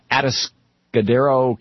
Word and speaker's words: Atascadero: Atascadero, California.